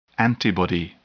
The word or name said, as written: antibody